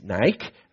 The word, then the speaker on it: Nike: O sea, como el tenis, Nike, realmente se debe pronunciar como se lee en español, Nike, que es victoria.